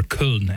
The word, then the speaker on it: Köln: St. Bruno of Cologne, or Köln, as it would be in Germany. We'll stick with Cologne because it's a little easier to pronounce and to hear pronounced.